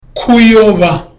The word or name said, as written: kuyoba